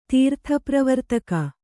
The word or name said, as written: ತೀರ್ಥಪ್ರವರ್ತಕ